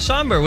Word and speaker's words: Sombr: Somber, S-O-M-B-R. – Oh, yeah, Somber.